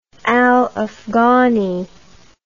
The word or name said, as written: al Afghani